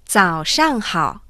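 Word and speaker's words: Zao Shang Hao (早上好): Buenos días en chino se dice Zao Shang Hao.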